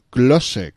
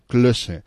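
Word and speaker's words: Klöße: i una cosa que se diu Klosse, que s'escriu en una Eszet, que és una espècie de beta, però que se pronuncia S, i que ho he vist en aquestes superfícies més d'una vegada i de dos, ahir a la venda.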